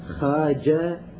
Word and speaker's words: خواجة: وإذا جاءت الخاء والواو والألف خواء، لا تلفظ الواو وإنما تلفظ الألف خاجة، مثل خنساري، خنساري هي خانساري، اللفظة الصحيحة خانساري، لكن تكتب خاوانساري، صاحب روضات الجنة خانساري، تكتب الخوانساري، هي الخانساري لفظها الصحيح.